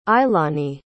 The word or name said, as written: ailani